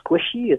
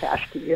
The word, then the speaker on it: Qashqai: Is it a squishy? Is it a Qashqai? Because it's also a Q's. Q's and S's and H's, but it's a Qashqai.